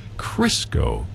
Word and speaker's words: Crisco: Kind of want to check out Crisco, rhymes with Frisco in San Francisco.